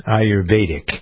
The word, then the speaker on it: Ayurvedic: As someone who was writing about Ayurveda, you pronounce this any number of ways, but I pronounce Ayurvedic wisdom, Ayurvedic.